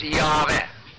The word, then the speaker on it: Yahweh: It was spelled in the Hebrew YHVH, but pronounced Yahweh.